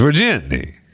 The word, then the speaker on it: Virginie: It's Virginie. – Virginie? – Virginie. – Okay. I'll give you because... V-I-R-G-I-N-I-E.